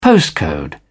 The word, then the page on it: postcode: British English